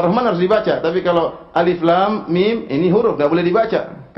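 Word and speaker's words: Alif Lam Mim: Alif, lam, mim. Jangan dibaca alam, keliru. Jangan dibaca alam, ya.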